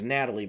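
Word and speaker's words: Nathalie: The girl I picked was, I think her name is Natalie, but it's spelt weird. It's N-A-T-H-A-L-I-E, Emmanuel, and she's from Game of Thrones.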